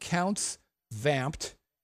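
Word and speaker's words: Count's Vamp'd: It's called Counts Vamped. Counts as in the possessive count. Counts Vamped. V-A-M-P apostrophe D. Counts Vamped.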